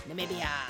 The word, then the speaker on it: Namibia: Namibia. – How do you say it again? – Namibia.